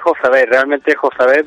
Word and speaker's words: Jozabed: No, realmente Jozabed, pero ya llevo bastante tiempo acostumbrado a que me llamen Joza, así que Joza me suena más habitual.